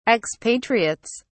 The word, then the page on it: expatriates: British English